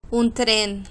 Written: un tren

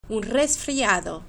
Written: un resfriado

